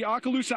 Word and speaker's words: Okaloosa: They've taken these tables, turned them upside down, and here you can see Okaloosa Island, as I've been told is the pronunciation.